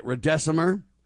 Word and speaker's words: remdesivir: Beware of, how do you call this thing? – Remdesivir. – That's how you say it. Remdesivir.